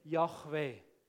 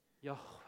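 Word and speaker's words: Jahwe: Und da heisst es, man soll es aussprechen, wie wenn es gehaucht wäre. Quasi so, reden mit Luft. Probiert das? Ja, genau. Darum sagen sie, der Name ist Yahweh. Yahweh.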